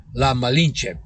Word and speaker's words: La Malinche: a Mayan-born woman known as La Malinche. That's spelled, by the way, M-A-L-I-N-C-H-E. La Malinche, period.